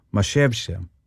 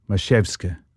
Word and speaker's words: Mazowsze: The performers, who I thought were quite striking, were called Maszewsze, and that is spelled, if you want to track them down, M-A-Z-O-W-S-Z-E. Maszewsze.